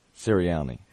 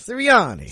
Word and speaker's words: Sirianni: You missed a couple of vowels. You called him Sirian. – Sirianni. Sirianni, yeah, my apologies. – Sirianni.